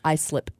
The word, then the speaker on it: Islip: Oh, you mispronounced it. It's Islip.